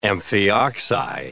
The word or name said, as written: amphioxi